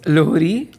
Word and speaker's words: Lohri: It's Lohri.